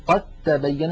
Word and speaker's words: قد تبين: لاحظوا النطق قد تبين، ننطق تاء مشددة فالدال قد أضغمت في التاء،